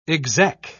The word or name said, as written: exec